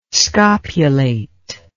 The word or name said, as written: scopulate